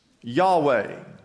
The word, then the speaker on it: Yahweh: Now, we pronounce this Yahweh. However, we put the vowels in there. When it was written in the Old Testament, there were no vowels. The name for God are simply those four letters.